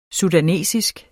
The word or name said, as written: sudansk